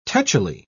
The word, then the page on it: tetchily: British English